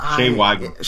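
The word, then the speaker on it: Shea Whigham: Shia Wiggum? Is that his name? – Shay Wiggum. – Shay Wiggum, thank you.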